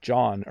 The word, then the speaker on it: John: Names like John are pretty easy. Although that H doesn't really do anything in John, does it?